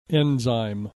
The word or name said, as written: enzyme